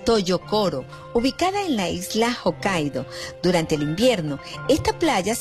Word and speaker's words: Hokkaido: Sí, búscala, porque se llama Hokkaido, pero se escribe H-O-K-K-A-I latina D-O, Hokkaido.